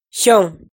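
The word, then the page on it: Xyooj: Hmong; last name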